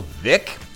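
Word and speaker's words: Veeck: Okay, coming into the first break, we discussed owner of the Chicago White Sox, Bill Vick, spelled Veach, but you pronounce it Vick.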